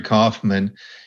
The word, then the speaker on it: Kaufman: So, one great place is Amazon, where you'll find the NCG Factor and Larry Kaufman.